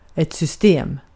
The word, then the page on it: system: [sʏstˈeːm]